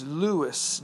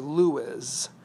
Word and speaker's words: Lewes: Lewis, Delaware. That's spelled L-E-W-E-S. Some people would say Lewis.